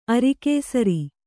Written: ಅರಿಕೇಸರಿ

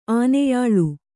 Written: ಆನೆಯಾಳು

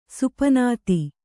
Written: ಸುಪನಾತಿ

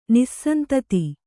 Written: ನಿಸ್ಸಂತತಿ

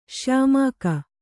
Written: ಶ್ಯಾಮಾಕ

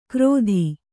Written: ಕ್ರೋಧಿ

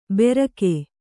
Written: ಬೆರಕೆ

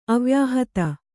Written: ಅವ್ಯಾಹತ

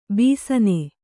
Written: ಬೇಸಿನ್

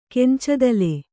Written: ಕೆಂಚದಲೆ

